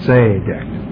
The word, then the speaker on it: tzedek: If you want the word, tzedek, or the emphasis, I think, is on the first part, tzedek. So many of the Hebrew words, the last syllable is emphasized, and this one is one of those strange ones where the first syllable, the accent, could be there.